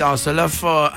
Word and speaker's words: Hasselhoff: E oltre a questa serie televisiva, David Hasselhoff, sì, è difficile, ma è così che si pronuncia, è famoso per Supercar, The Night Driver, Supercar con la macchina Kit che guidava.